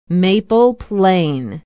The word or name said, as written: maple plain